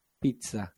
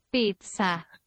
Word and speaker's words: pizza: Ya, pero es con T, no con C. No es pizza. – la pronunciación es pizza. Pizza, no pizza. Según el vocabulario de la lengua italiana, de Nicola Ciganarelli, el grafema doble Z corresponde al fonema TTS, pizza, pizza, para los hispanohablantes, pizza, ah, ya, pizza, pizza, para los hispanohablantes, pizza, ya que no es posible articular el fonema T duplicado.